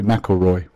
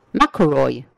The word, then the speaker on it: McIlroy: How do you pronounce that? Is that McElroy? McElroy. – Only the biggest golfer in the world. – So is that how you pronounce him? McElroy. No. How'd you say it? – Rory McIlroy. – McIlroy.